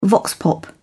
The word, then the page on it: vox pop: British English